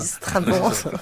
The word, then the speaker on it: Strabon: Oui, S-T-R-A-B-O-N. Ça se prononce Strabon.